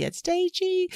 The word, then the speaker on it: Adedeji: forward slash Jackie Adedeji.